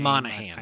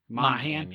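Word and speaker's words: Monaghan: Mohegan of Domino's Pizza. Excuse me if I mispronounced his name. – Tom Monahan. – I know. Monahan, yes. Okay.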